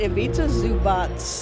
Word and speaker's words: Ivica Zubac: How do you pronounce Ivica Zubac? – I pronounce it Ivica Zubac. – Zubac. – Like Zubac. It's like TTZ at the end.